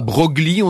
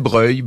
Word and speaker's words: Broglie: On disait pas Broglie, on disait Breuil.